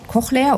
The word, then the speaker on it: Cochlea: Wir sehen da Trommelfell, Gehörknöchelchen, Cochlea, habe ich es richtig ausgesprochen? – Ja, genau. Ja. Cochlea oder Hörschnecke kann man auf Deutsch auch sagen.